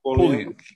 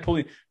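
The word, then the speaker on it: Pulliam: Keisha Knight and Pullian. Am I saying it right? – Pullian. – Keisha Knight Pullian, who most people will know from Cosby fame.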